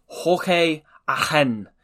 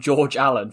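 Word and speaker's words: Jorge Allen: As the commentator once referred to him as, just to point out that that's his Argentine pronunciation of his name, but he was just going to call him George Allen for the rest of the game because he is of English parentage, but was born in Argentina.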